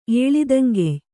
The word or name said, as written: ಏಳಿದಂಗೆಯ್